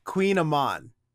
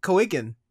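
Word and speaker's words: kouign-amann: Why is it pronounced Queen Amon? And it's spelled K-O-U-I-G-N, but they decide to pronounce it Queen. Why don't they just spell it Q-U-E-E-N?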